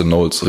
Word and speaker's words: Knowles: ah, KN wird N ausgesprochen und dann weiß man auch Wörter wie Knowing oder Namen wie Beyoncé Knowles richtig auszusprechen.